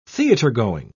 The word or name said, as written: theatergoing